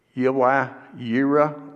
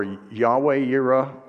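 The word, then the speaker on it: Yahweh Yireh: Uh, it's Yahweh Yira or Yahweh Yira, or you may have heard the old um, German pronunciation Jehovah Jireh. If you've heard that, that's just a, you know, that's how the Germans pronounce it.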